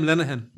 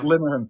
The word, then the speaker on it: Linehan: But I forget how to pronounce his surname. Linehan? – Graham Linehan, yeah. – Linehan, thank you.